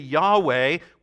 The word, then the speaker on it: Yahweh: And to pronounce that name, the Jews used the vowels from the name Adonai, which means Lord, capital L, then lowercase O-R-D, and turned YHWH into Yahweh, which is translated Lord in all caps.